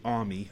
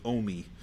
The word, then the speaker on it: AUMI: And so that was the start of what became the Adaptive Use Musical Instrument, or AUMI, which is pronounced variously. I say AUMI. I think Ellen says OMI, but, you know, whatever.